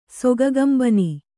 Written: ಸೊಗಗಂಬನಿ

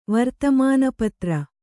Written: ವರ್ತಮಾನಪತ್ರ

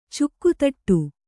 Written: ಚುಕ್ಕುತಟ್ಟು

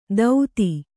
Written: ಧೌತಿ

